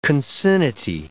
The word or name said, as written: concinnity